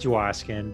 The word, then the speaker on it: Dwoskin: I started doing these videos to promote myself, and they're like, "Oh, I didn't know that's how you pronounce your name, Dwoskin." And I'm like, "That's not how you pronounce my name." And they're like, and I go listen to the videos, and like, when I get excited, I say my own name wrong.